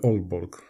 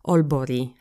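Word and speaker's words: Aalborg: Tak, ja właśnie y, mam gdzieś tam znajomych z Olborga i oni mnie też tam zawsze poprawiali, że, że Olborg, nie tak. – Alborg. Olborg, albo jeszcze tak podpowiem tu wam, że to G czytamy takie I jakby Olbori, Helsingbori się powinno czytać.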